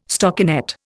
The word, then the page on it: stockinet: Indian